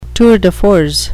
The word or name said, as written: tour de force